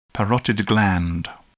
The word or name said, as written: parotid gland